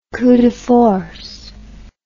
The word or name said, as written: coup de force